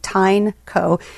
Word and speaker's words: Tineco: It's spelled T-I-N-E-C-O, like Tineco, but it's called Tineco.